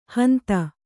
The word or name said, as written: ಹಂತ